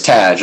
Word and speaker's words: Tadge: It's you, just like you said, it's Tadge. Like Tadge. Badge, but with a T.